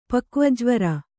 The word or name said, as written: ಪಕ್ವಜ್ವರ